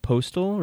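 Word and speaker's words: Postl: This was such a waste of, I think his last name is pronounced like Postal, right? Dylan Postal? – Yes.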